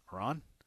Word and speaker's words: Maron: Maroon, I don't even know how to pronounce that. Maron, M-A-R-O-N. How do you say that? Maron, M-A-R-O-N.